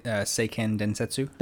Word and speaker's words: Seiken Densetsu: And then Final Fantasy Adventure is the first Mana game, which in Japan is called Seiken Densetsu. Did I say that right? – Seiken Densetsu. – Thank you.